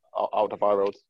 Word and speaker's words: Alderweireld: I was going to say, with Alderweireld, did I say that right? Alderweireld, how'd you say it? – Alderweireld, yeah.